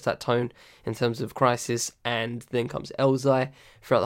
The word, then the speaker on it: Khrysis: You know, Crisis, part of the... it's spelled K-H-R-Y-S-I-S, by the way.